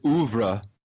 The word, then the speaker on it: oeuvre: Like, if you look back at Luc Besson and what he's done with his life on film, his oeuvre, if you will.